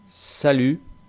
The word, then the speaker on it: salut: La T final que aparece en salut tampoco se pronuncia. Es que las T finales en general en francés no van a sonar. Siempre que vemos una T final es como si no estuviera. Por ejemplo, carnet, chocolat, salut.